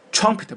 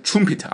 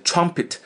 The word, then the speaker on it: trumpet: 原句说撞到这个 trumpet 是乐队里面那个小号喇叭 trumpet，注意发音 trumpet，不要念成 trumpet 啊，是 trumpet 还有一个喇叭，我这里呢要注意，这个 trumpet 一般是小号啊，这个乐队里还有个汽车喇叭，会用 horn H-O-R-N horn 啊，韩老师在这里帮你标注了啊， horn，这是汽车那个喇叭，好不好？